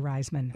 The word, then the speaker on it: Raisman: Yeah, I think I'm saying it wrong. I think it's Allie Raisman. – I think it is, too.